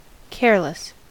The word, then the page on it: careless: American English